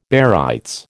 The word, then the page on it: barytes: American English